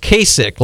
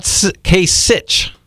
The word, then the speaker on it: Kasich: And so get out a pen and paper or listen, because I'm going to give you the latest on Trump, Cruz, and Kasich. Yes, his name is pronounced Kasich, like someone's sick, okay? It's not Kasich.